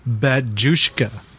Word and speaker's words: Batushka: The band name should be pronounced as Batushka.